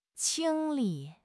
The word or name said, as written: 清理